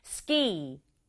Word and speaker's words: ski: Ski, ski. 우리말로도 스키, 근데 영어는 한 박자로 ski 발음하고요.